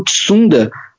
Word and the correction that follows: Tsunoda: apareceu, acho que é essa pronúncia, sou péssima em pronúncia Tsunoda. asiática. Tsunoda, Tsunoda. Isso.